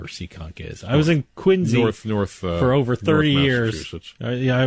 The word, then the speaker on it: Quincy: Even though the trust is from Quincy. – With a Z. That's how you pronounce it.